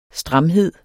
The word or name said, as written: stramhed